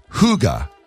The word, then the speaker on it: hygge: But the word is hygge.